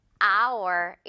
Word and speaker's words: hour: Our 이렇게 읽잖아요. 그쵸? 이 H는 지금 소리가 나지 않아요. 그래서 그냥 Our라는 이런 단어도 있잖아요. 이렇게 발음 똑같이 해주면 돼요.